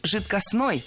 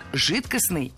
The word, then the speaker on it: жидкостный: Да, итак, прозвучали варианты «жидкостный» и «жидкостной». А какой из них верный? Вариант «жидкостный» является литературной нормой. Ударение исходного существительного «жидкость» в нем сохраняется, но окончание должно быть «ый». «Жидкость» – «жидкостный».